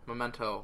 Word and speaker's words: Memento: So, otherwise, I've also been working very hard on a little game called Memento. – What was that? I feel like you mispronounced it. – Memento, which is our current project, which you'll find out more about soon.